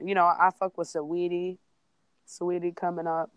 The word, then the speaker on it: Saweetie: Um, I never knew how to pronounce her name though. You you just told me just now though. That's tight. – Saweetie, yeah, she's she's the truth for sure.